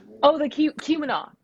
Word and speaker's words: Keweenaw: The Keweenaw. – Keweenaw? – Okay. Keweenaw Soundscape Project. Yeah, so Keweenaw is an Ojibwa word for portage. Which I live in the Keweenaw Peninsula, and I actually live on basically what's called Copper Island.